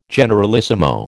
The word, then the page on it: generalissimo: American English